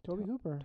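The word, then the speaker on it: Tobe Hooper: Toby Hooper.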